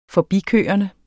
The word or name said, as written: forbikørende